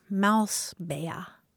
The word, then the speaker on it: Mausbär: B-A with an umlaut, R, Mausbär.